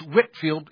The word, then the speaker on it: Whitefield: Notice it's spelt Whitefield, but it's Whitefield in pronunciation.